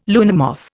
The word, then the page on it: luna moth: Indian